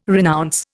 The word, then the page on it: renounce: Indian